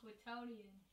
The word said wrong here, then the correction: quotidian: Go ahead and say it correctly. Quotidian? – Quotidian. Oh, okay.